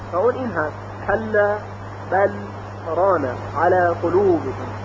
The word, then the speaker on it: بل ران: در روایت 7 یعنی قرآن موجودی که در دست ما هست و اون رو قرائت میکنیم، لام ساکن در حرف را استثناء در اینجا ادغام نشده، بل ران، بلکه لام به صورت ساکن تلفظ میشه بل، و با یک فاصله کوتاه بدون اینکه تنفس بکنیم کلمه بعدی خونده میشه، بل رانه. باز تکرار میکنیم بل رانه.